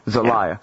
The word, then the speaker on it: Zelaya: Honduran President Manuel Zelaya, is that how you say it? – Zelaya.